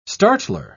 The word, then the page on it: startler: British English